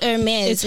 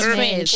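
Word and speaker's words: Hermès: Oh, so it's not Hermes. We're saying it wrong. – Yeah, it's Hermes. It's Hermes. – Why don't you fix us? Why don't you fix us? – I'm fixing you now. It's Hermes.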